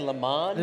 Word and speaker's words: Le Mans: Did you say Le Mans? – Le Mans. – Le Mans. – I went to Le Mans. – The N is silent.